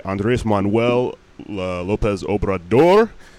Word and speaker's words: Andres Manuel Lopez Obrador: This guy, Andres Manuel Lopez Obrador.